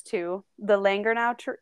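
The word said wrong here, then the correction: Llangernyw: yew tree. So it's Langernyw.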